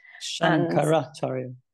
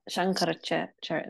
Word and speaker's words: Shankaracharya: But it got me thinking, because he goes on to say, you know, the Buddha and Shankaracharya, am I saying that correctly? – Shankaracharya. Yeah. – Shankaracharya.